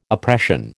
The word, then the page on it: oppression: American English